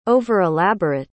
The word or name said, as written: over-elaborate